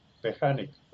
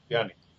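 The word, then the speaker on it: Pjanić: Uno es Pjanic, se escribe P-J-A-N-I-C, Pjanic, pero se pronuncia Pjanic. – Pjanic, correcto.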